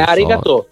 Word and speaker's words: Arigato: è Arigato.